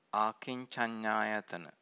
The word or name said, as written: akincannayatana